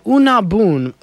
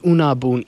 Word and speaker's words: Underbyen: Something kind of interesting, a small unknown group, well, maybe known, but new to me, called Unabun, but spelled U-N-D-E-R-B-Y-E-N, pronounced Unabun. It's Danish.